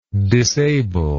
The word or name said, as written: disabler